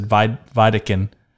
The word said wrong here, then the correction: Vicodin: and it's Vicodin.